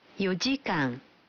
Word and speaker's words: 4時間: certains nombres sont prononcés différemment.4 heures se dira 4時間